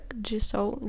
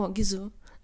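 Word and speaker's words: Gisou: Так, Jisoo для волосся. Такі. – А, Gisoo? – Да. О, Gisoo.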